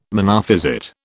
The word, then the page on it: Monophysite: British English